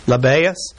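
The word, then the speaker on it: Lebbaeus: and how do you pronounce that? Tell me. – Labaius, whose surname was Thaddeus, Simon the Canaanite, and Judas Iscariot, who also betrayed him.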